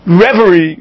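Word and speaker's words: reveille: When was the last time you heard reverie, or what's it called? Did I say that right? – Yep.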